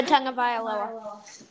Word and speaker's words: Tagovailoa: Just for everyone in Miami who wants to know how to pronounce Tua's name, Tagovailoa. I'm a Bama fan, so I know that. – Tua Tagovailoa.